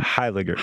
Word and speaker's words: Heyliger: Heyliger.